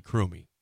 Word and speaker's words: Krumme: Am I saying it right? Crummy? Crummy. Sorry. – Crummy. – K-R-U-M-M-E. Crummy.